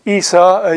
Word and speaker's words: Isa: So Islam believes in Jesus, known to them as Isa, Al-Masih, Isa is how it's pronounced.